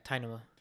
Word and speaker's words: Tynamo: Tynamo. How do you pronounce that? – Yeah, Tynamo.